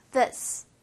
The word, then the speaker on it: this: th는 자, 앞니보다 혀가 살짝 앞으로 나와요. 에이. 그 다음에 요 앞니랑 혀 사이로 바람을 그 상태로 this.